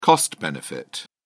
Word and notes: cost benefit: British English

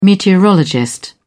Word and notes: meteorologist: British English

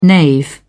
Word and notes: knave: British English

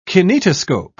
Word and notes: kinetoscope: British English

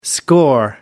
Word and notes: score: American English

